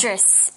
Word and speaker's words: Idris: OK, 不要搞错哦。OK, 我们看回刚刚的例句提到的，就是呢，How do you pronounce this? Idris? – Idris.